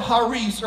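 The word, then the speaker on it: charis (χάρις): In the Greek, the word grace is the word charis, C-H-A-R-I-S. The C is silent in Greek, and it's actually pronounced charis.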